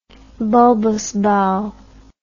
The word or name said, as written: bulbous bow